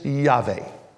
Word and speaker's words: Yahweh: Best pronunciation of the three Hebrew consonants is Yahweh. So we say in Hebrew, Yahweh. As Gentiles, we say Jehovah, okay?